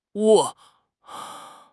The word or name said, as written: 窝